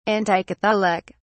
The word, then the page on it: anticatholic: British English